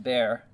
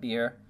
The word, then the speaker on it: Beer: And it says from there they went to, it's pronounced Bear, not Beer, which means a well.